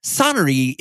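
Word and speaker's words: sonnerie: Yes, I did. And I think I pronounced it right. I feel like this segment is a bit turning into the dictionary pronunciation episode, but sonnery is French for making sound or ring.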